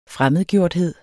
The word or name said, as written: fremmedgjort